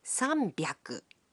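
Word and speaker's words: さんびゃく: 300 is pronounced sanbyaku. 600 becomes ropyaku. And 800 is pronounced hapyaku.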